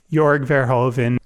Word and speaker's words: Verhoeven: Like, how do you pronounce? Is it Verhoeven? – Jorg Verhoeven. Yeah, so Jorg Verhoeven and Barbara Zangrill, Babsy.